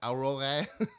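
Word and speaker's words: Aurore: It's A U R O R E. Pronounce it in French. – Aurora.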